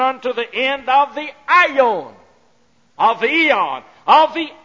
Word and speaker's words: aeon: Then the third Greek word that is translated world is aeon. We've taken it in English and pronounce it eon. Aeon.